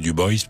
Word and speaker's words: Dubois: Non loin de Dubois, enfin la ville de Dubois, qu'il faut maintenant prononcer Dubois, mais Dubois, c'est évidemment un colon français qui a laissé des traces là-bas dans le Wyoming.